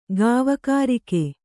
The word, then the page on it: ಗಾವಕಾರಿಕೆ: Kannada